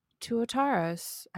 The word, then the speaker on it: Tuataras: And if you're not a fossil person, then Tuataras.